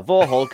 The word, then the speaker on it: Vauxhall: And I was like, how do you say that? And like, oh, Vorhol Cavalier.